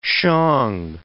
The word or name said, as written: Shang